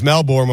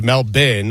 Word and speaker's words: Melbourne: Well, I thought it was Melbourne when we started today, but it's Melbourne, and that is courtesy of Noah Eagle.